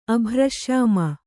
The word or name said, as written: ಅಭ್ರಶ್ಯಾಮ